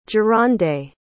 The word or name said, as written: jerande